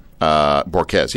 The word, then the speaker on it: Borges: But like I just said to our second last author, Michelle Borges, I can't remember how to pronounce her name. Borges, yeah, I said it right.